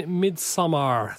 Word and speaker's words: Midsommar: Tell us, you've seen Midsommar.